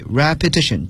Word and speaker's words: repetition: 但是 repeat 大家注意它在变作名词时呢读音有了一点变化， repetition， T-I-T-I-O-N 结尾， repetition 重复， repeat 是重复。